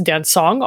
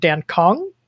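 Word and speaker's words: Dancong: And so this style, is generally pronounced dansong, although it's spelled dankong. So it kind of looks a little confusing, but we say dansong.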